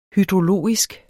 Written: hydrologisk

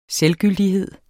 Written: selvgyldighed

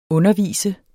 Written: undervise

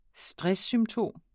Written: stresssymptom

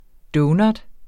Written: donut

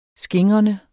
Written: skingrende

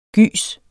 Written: gys

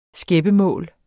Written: skæppemål

